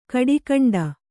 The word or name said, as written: ಗಿಡಗಂಟೆ